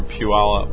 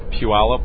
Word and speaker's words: Puyallup: Yeah, we just went from Sumner, and now we're going through Puyallup. – Say that again? – Puyallup. – I don't think I can pronounce that. Wow, Puyallup.